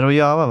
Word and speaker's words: Rojava: Hur uttalar man det här? – Rojava, va? – Rojava.